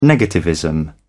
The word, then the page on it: negativism: British English